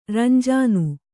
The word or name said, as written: ರಂಜಾನ್